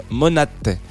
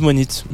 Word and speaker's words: Monate: Il est temps de commencer doucement mais sûrement cette euh, émission spéciale sur Steve Monate. Euh, qui s'écrit Steve, Steve Monit, mais visiblement ça se dit Monat.